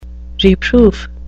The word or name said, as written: reproof